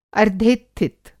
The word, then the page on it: अर्द्धेत्थित: Hindi